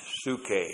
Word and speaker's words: psuche: P-S-U-C-H. Long E, suke.